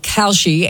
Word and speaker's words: Kalshi: I am saying it right, Kalshi? – Yeah, you're right.